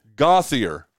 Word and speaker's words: Gauthier: Rob Gauthier? – That's French. – Okay. – Gauthier is English. – Gauthier.